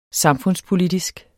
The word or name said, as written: samfundspolitisk